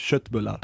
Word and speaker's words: köttbullar: Jetzt muss ich mal kurz fragen, du hast gerade Köttbullar gesagt, weil ich habe nämlich schon mal gehört, einige sagen Schöttbullar oder so. Was ist denn richtig? Wie spricht man es denn richtig aus? – Ja, du sagst das richtig. Ich weiß nicht, ich probiere das Deutsch zu sagen oder etwas, aber das heißt eigentlich Schöttbullar.